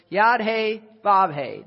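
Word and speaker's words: Yod Hey, Vav Hey (יהוה): All right, Y-H-W-H. The letters themselves, Yod, the Y, Yod, and then the H, Hey, is how we pronounce it. Yod Hey, Vav Hey.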